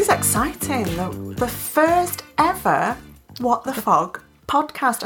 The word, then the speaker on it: What the FOD: I said it right. – You did say it right, because I say what the pod podcast most of the time, which was a bit strange because we're starting this new little venture and I can't even say the right title.